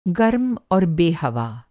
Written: गर्म और बेहवा

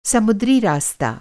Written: समुद्री

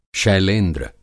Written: शैलेंद्र

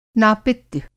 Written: नापित्य

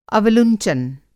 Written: अवलुंचन

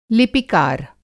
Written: लिपिकार

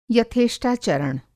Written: यथेष्टाचरण

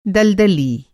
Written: दलदली

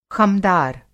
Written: ख़मदार

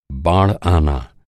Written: बाढ़

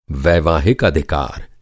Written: अधिकार

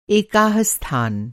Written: एक

